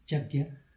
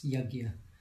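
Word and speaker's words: yajña: If it's the Bengali style, it's Jagya. – Jagya. – But the sort of the standard sort of in most of India it's Yajna. – Yajna. Yajna tapasam. Yajna.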